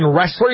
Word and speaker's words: Ressler: Ryan and Megan Ressler, you said? – Yep. They're from Cooperstown, North Dakota. – How do you spell that, the last name? – Wrestler, just like all the other wrestlers. – R-E-S-S-L-E-R.